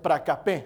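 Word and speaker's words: pracapé (προκοπή): Esta palabra progreso viene de una palabra griega que se pronuncia pracapé. A ver, di pracapé.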